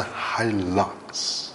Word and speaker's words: Hilux: 啊，车这个东西简单来讲呢，都是 four wheel drive 的类型，而 Hilux 呢是多大旗下的一个 four wheel drive 的车款，它的车耐用性可靠性大家都懂， is the best of the best。刚刚你讲的 Hilux Hilux 其实真正的读音呢是 Hilux。